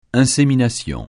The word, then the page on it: insémination: francais